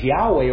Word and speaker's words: Yahweh: That's representing the Tetragrammaton, those four sacred letters in the Hebrew, transliterated to YHWH, which we pronounce as Yahweh or Jehovah.